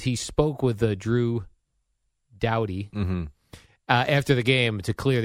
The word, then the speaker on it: Doughty: Of Kings, the defenseman, Drew Doughty. – Doughty. Good try, though.